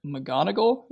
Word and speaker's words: McGonagall: Another thing that sort of, I guess, confused me, the conversation between Dumbledore and Professor McGonagall, is that how you say that? – I am disappointed in how accurately you pronounce that name, yes.